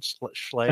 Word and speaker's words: Schlage: Is that the right pronunciation? Schlage? – I think so. That's how I've always said it.